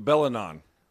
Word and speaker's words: Belingon: Be- Belingon, uh, or yes. – The G is silent.